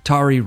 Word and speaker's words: Tari: Tari Rhymes with Jabari,